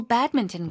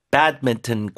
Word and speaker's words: badminton: Do it properly on a real badminton court. 자, 이왕 하나미의 배드민턴 발음을 조금 좀 도와드리고 싶은데 badminton입니다. 자, 앞에 강세 주시고요.